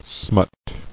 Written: Smutted

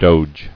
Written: Doge

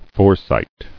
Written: Foresighted

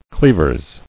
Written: Cleavers

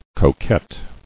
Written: Coquet

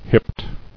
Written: Hipped

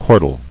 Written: Chordal

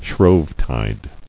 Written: Shrovetide

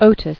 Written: Otis

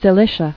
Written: Cilician

